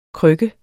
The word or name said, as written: KRYKKE